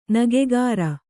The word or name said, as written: ನಗೆಗಾರ